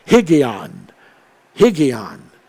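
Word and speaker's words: Higgaion: And then it just simply puts in the word Higg, H-I-G-G, so it's pronounced Higgion, Higgion.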